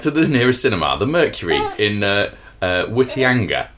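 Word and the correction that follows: Whitianga: It's pronounced Fittyanga. Okay, Fittyanga. We chose Paddington 2, 10 Chris's.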